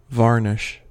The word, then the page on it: varnish: American English